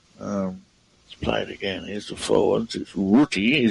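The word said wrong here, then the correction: Rutte: Ruta. – Ruta.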